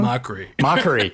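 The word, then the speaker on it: Mochrie: This dude obviously is very funny, and this dude is Colin McRae. Did I get it wrong? – Mockery. – God. – Mockery. 2 syllables.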